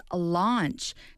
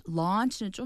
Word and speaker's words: launch: All right, the first expression from this is launch. To be confused with the pronunciation lunch. – The thing you eat lunch. – 그죠? 점심은 lunch. Launch는 좀 더 이렇게 입을 좀 더 확장시켜서 맞습니다.